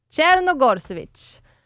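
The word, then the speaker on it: Crnogorčević: Nu ska ni få höra hur man uttalar vår gumma. För jag har till och med googlat på det här. För att det här är så jäkla komplicerat. Hon spelar Barcelona. Czernogorsevic. – Men du hade ju valt det schweiziska franska uttalet. Det var ju liksom någon schweizisk variant av det hela.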